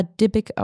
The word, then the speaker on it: Dybbuk: Es wird Dybbuk geschrieben, aber Dybbuk ausgesprochen.